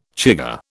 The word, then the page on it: chigger: British English